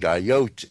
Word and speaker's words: Ayotte: I think Ayotte is the way you pronounce her last name, A-Y-O-T-T-E, of New Hampshire.